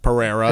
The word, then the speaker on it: Pereira: Pereira, that's if you good. Read it. Like, it's obviously you're saying it correctly, but if I'm reading that, like.